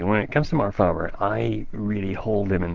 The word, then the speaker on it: Faber: or Faber, as people, I think it's most frequently pronounced Faber.